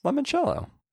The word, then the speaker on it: limoncello: It is Limoncello. That's how you pronounce it.